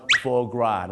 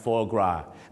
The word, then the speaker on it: foie gras: ครับเขาออกเสียงว่า foie gras นะฮะ foie gras ไม่ใช่ฝอยกราสนะ